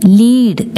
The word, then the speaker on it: lead: LEAD ലീഡ് എന്ന് വായിച്ചാൽ നയിക്കുക എന്നാണ് അർത്ഥം. LEAD ലെഡ് എന്ന് വായിച്ചാൽ ലോഹം എന്ന അർത്ഥം വരുന്നു.